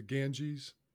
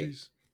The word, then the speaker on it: Ganges: The three G's are the Ganges, the sacred river in India where the cleansing of sin occurs, the river's the Ganges. Okay. Yes. Okay. What'd I say? Ganges. Yes. Right. I said it wrong.